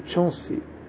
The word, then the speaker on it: Chance: O livro diz: era um domingo e Chonsi estava no jardim. Ele é, esse Chonsi se escreve chance, é chance em português, se escreve chance, né?